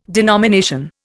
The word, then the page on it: denomination: Indian